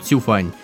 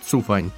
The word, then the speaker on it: Цюфань: Все-таки имя пишется через «ю», но поскольку китайское имя, наверное, его правильнее произносить через «ю» — «Цюфань». Но иногда мы будем говорить «Цюфань». Думаю, не так критично.